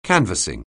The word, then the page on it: canvassing: anglais